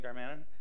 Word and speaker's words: Darmanin: My name is Tony Darmanin. You can pronounce it any way you want. My last name is Maltese.